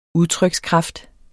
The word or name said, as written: udtrykskraft